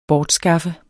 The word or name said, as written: bortskaffe